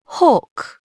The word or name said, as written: hawk